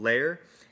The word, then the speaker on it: Layar: it's called Layer. It's spelled L-A-Y-A-R.